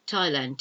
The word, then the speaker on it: Thailand: Now this time is a herb, and it's T-H-Y-M-E, time, T-H-Y-M-E. And obviously a country that we can think of is Thailand. You don't pronounce the H in Thailand, not in English anyway. Thailand, T-H-A-I-L-A-N-D, Thailand.